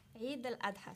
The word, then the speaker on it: Eid al-Adha: Y que voy a intentar pronunciarla bien. No sé si me voy a acercar un poquito. Entonces, la siguiente pregunta es si tú celebras la Eid al-Adha, o celebración del sacrificio, y qué mística o simbología rodea al cordero en este banquete. – Eid al-Adha se dice.